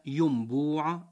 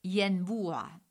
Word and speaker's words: ينبوع: مستمعين الكرام، أن نقول ينبوع بضم الياء نريد عين الماء، فإنه ضبط غير صحيح، وهو من كلام العامة. – الصواب أعزائنا الكرام ينبوع بفتح الياء كما ورد في المعاجم اللغوية وكما نطقت العرب.